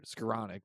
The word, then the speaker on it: Skowronek: We have Skowronek, I think is how you say it. Skowronek, that's it.